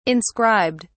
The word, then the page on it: inscribed: British English